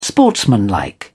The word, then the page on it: sportsmanlike: British English